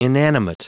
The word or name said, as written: inanimate